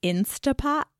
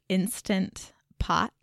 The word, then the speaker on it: Instant Pot: He said that I say it like Instapot when it's actually Instant Pot. So listeners, just be aware that that's what it actually is.